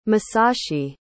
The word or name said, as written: Masashi